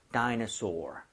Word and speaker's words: dinosaur: 하지만 여기서는 dinosaur. soar처럼 발음을 좀 해주시면 네.